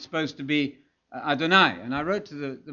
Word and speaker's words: Adonai: It rhymes with El Shaddai, and he almost to sing you the song, da da da da da da da da da, and everybody knows El Shaddai. You remember, okay, El Shaddai rhymes with Adonai.